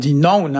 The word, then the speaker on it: naon: Et alors, ça s'écrit N-A-O-N. Alors, il y a des prononciations un peu variées selon les endroits. Hein. Ici, on dit noun, hein, très, très diphtongué.